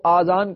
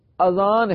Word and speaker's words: اذان: اور اذان کی اتنی فضیلت بیان کی گئی ہے، آپ کو عرض کرتا ہوں، پہلے یہ تلفظ بھی درست کر لیں، بعض لوگ اذان کو آذان کہتے ہیں، آذان تلفظ نہیں ہے، آذان جمع ہے اذن کی اور اذن کے معنی ہے کان، درست تلفظ اذان ہے، یعنی الف پر زبر ہے، الف کے اوپر مد نہیں ہے، اذان.